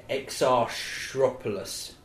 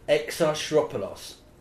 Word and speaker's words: Exarchopoulos: The lead's called she's of a Greek origin, Adele Exarchopoulos. That is pronouncing correctly. Let's make no bones about it. – No, it's pronounced with that natural Greek hesitation, yeah, in the middle.